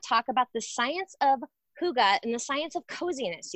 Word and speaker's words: hygge: So it's called hygge, but it's actually spelled H-Y-G-G-E. And I'm sure I'm sure everyone has seen this. H-Y-G-G-E.